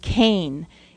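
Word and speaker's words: Cain (חן): It's pronounced Cain. It's C-H-E-N, but it's pronounced Cain.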